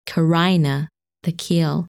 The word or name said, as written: Carina